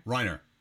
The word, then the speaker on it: Reiner: I also know that he loves how Raymer, is it Raymer? How do you say his name? – Who? – The producer. – Reiner.